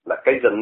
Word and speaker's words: Cajun: Calhoun Navy, tức là hải quân Calhoun, Cajun, cái chữ này phải đọc là Cajun, Navy.